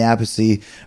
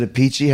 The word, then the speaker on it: Appice: But I just think that Dio band with that drummer of, of Vinny Appice, or is it a Peachy? How do we say it, Frank? – Well, it's funny if I if I'm not mistaken, it's a piece.